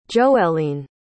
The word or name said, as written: joeliyn